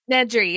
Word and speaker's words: Nedry: Nedry, yeah.